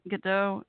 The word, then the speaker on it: Gadot: Oh, Gadot. G A D O T's last name, right? The actress that played Wonder Woman. Why am I blanking out on her first name? Almost, is it Gal Gadot?